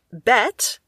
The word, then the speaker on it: Bête: And bet, B with the accent T, and then there's a silent E, but you can ignore that. – Best.